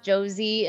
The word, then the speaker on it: Josee: That was I Know Waltz from Jose the Tiger and the what? – Pronounced Josie. So it's Josie the Tiger and the Fish.